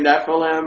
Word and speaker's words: Nephilim: I'm trying to spit out their name, Nephilim or Nephilim? – Nephilim.